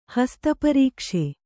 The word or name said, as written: ಹಸ್ತಪರೀಕ್ಷೆ